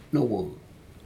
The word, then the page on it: nowoc: Aneityum